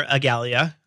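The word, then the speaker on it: Igalia: Because you work for Agalia. Is that how you say it? – Yes.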